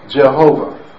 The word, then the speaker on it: Jehovah: We understand and know, based on the Hebrew grammar and notation and their pointing system and vowel system and so forth, that that name should be pronounced Jehovah.